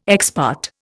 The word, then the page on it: ex parte: Indian